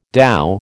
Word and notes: dhow: American English

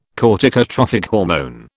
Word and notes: corticotrophic hormone: British English

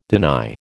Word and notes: deny: American English

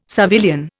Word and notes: civilian: Indian

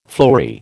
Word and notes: fleury: American English

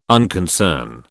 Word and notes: unconcern: British English

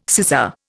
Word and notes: scissor: Indian

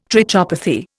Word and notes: trichopathy: Indian

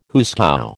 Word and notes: hoosegow: American English

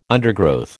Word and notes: undergrowth: American English

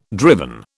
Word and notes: driven: British English

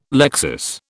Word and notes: lexis: British English

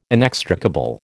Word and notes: inextricable: American English

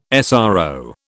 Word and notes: SRO: British English

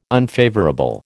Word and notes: unfavourable: American English

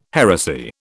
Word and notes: heresy: British English

